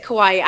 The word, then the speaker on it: Kauai: So it's Kauai. I had to get the pronunciation from some locals a few times before I got it.